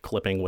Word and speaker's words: CLPPNG: I've heard them pronounce their own album name CLPPNG. It's just Clipping without the vowels and in all caps.